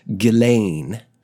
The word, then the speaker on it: Ghislaine: And Lady Ghislaine Maxwell, and yes, her first name, though funny spelt, it is pronounced Ghislaine.